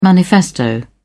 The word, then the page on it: manifesto: British English